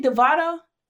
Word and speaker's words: DeVito: Devito? – Yeah, DeVito.